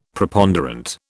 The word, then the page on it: Preponderant: British English